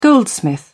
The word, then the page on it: goldsmith: British English